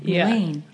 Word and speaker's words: Melayne: And when I met Cameron, I was like, Melayne Shayne. Because people oftentimes struggle with my first name. They don't know how to pronounce right. – it. And I'm like, just like Shayne, Melayne.